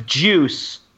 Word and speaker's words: Guice: How do you pronounce this running back's name for the Redskins? – Cox. – Juice? Goose? Darius? – Darius Goose? Goosey? Or is it, it's Juice. – G-U-I-C-E.